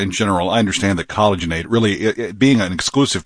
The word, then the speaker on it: Collagenate: It's collagen. It's collagenate. So there's an A-T-E on the end, just like rejuvenate, but it's collagenate. – Exactly. So it's collagenate, just like rejuvenate.